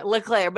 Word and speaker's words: Leclerc: Leclerc, but it's spelled like Leclerc.